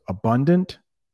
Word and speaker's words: abundant: Yeah, so abundant, abandon. Abundant, there's that hard T at the end. Abandon. Yeah, slight difference in pronunciation.